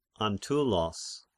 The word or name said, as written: Antyllos